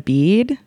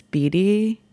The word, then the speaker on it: Beattie: There's just one E at the end. – Okay. – People are like, Panina Bead? – Bead. It's fine. – Yeah, it's fine. It's fine. I'm not going to get up at a mic and be like, actually, it's Beattie.